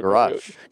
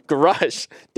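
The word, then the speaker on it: Grusch: Grush. – Grush.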